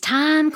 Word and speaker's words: Thyme: My name's Time Quinn, that's spelled T-H-Y-M-E, and I'll be sharing the time with you doing what I do every morning, having a talk with God.